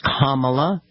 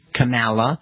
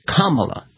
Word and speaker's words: Kamala: It's Kamala, not Kamala, not Kamala. It's Kamala.